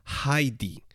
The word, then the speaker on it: Heidi: En alemán, el diptongo EI se pronuncia como AI. Es decir, que si lo pronunciásemos correctamente en alemán, tendríamos que decir siempre Heidi